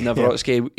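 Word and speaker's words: Nawrocki: I don't think, and then obviously Navrotsky, is that the pronunciation? – Navrotsky. – Navrotsky, yeah.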